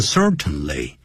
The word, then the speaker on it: certainly: 他说我们的确肯定的 definitely,就是确然的，也就是 certainly, C-E-R-T-A-I-N-L-Y。这个时候不要念成 certainly, 通常念快的时候叫 certainly, 用嗯的音，用鼻音。We all certainly need,